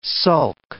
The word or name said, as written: sulk